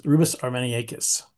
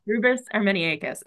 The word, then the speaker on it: Rubus armeniacus: Rosa armeniacus, Rubus armeniacus. – Rubus armeniacus.